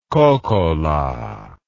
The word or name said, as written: kokkola